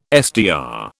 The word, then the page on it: SDR: British English